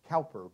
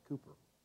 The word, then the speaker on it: Cowper: It looks like it would be pronounced Cowper, but it's actually pronounced Cooper.